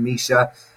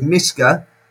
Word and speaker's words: Mischa: By the way, Ben, you did excellently at pronouncing Mischa, which means the gift of happiness. But if you said Mischa, that would be the gift of joy. And you can also say Mischa, which is the gift of God, I believe.